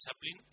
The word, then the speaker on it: Chaplin: Es Chaplin, la película de 1992, dirigida por Richard Attenborough.